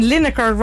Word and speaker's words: Lineker: He should get a prize for getting Lineker rhymes with vinegar.